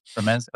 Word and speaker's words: Ramez: Is it Ramez Nam? – Yeah. – Yeah. – Ramez.